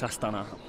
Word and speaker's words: Astana: You pronounce it Astana. Can you explain? Is that the correct pronunciation? – Yes, I've been to Kazakhstan, and I had to look it up. So yes, it's Astana, apparently.